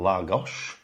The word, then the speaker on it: Lagos: We are currently situated in Lagos. – Spelled L-A-G-O-S, but pronounced L-A-G-O-S-H, because they like to do that in Portuguese, don't they?